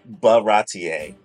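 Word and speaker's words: Baratie: So one of them ended up at Batier. – You're so close. Baratier. – I'm missing a syllable.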